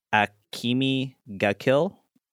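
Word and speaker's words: Akame ga Kill: It's I don't want to pronounce it wrong, so it's Akimi ga Kill.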